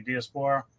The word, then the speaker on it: Diaspora: Did I pronounce that right, Diaspora? – Yeah, fantastic. That's right.